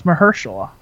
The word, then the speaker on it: Mahershala: It's with, Mahershala Ali, however the hell you say his first name. And that's exactly how you say it.